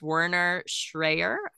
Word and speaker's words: Werner Schreyer: So his name is Werner Schreyer. I hope I'm saying that correctly. – And that sounds right.